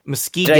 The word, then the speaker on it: Muskegon: So okay, how many people are in Muskega? – Muskegon. – Did I say it right? – Muskegon.